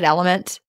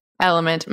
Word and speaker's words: LMNT: I always want to call it LMNT, by the way. But you pronounce it Element? – Element. Mm-hmm. Yeah. – LMNT. It's spelled LMNT for anyone who's wondering. All right.